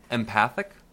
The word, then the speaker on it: empathic: I always thought it was empathetic, but it's spelled E-M-P-A-T-H-I-C. – Empathic? – Empathic, you got it.